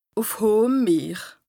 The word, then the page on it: ùf hohem Meer: Bas-Rhin; Strasbourg